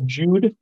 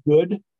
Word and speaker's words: Jude: It's called Jude, or it's actually pronounced good, but spelled like Jude, J-U-D-E.